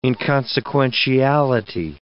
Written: inconsequentiality

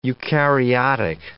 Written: eukaryotic

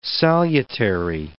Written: salutary

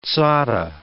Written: sadhe

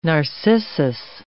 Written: narcissus

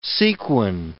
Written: sequin

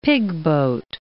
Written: pigboat

